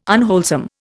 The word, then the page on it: unwholesome: Indian